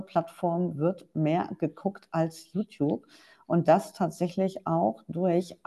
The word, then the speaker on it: YouTube: Ich würde sagen, YouTube. – Gewonnen. – YouTube ist richtig ausgesprochen, genau.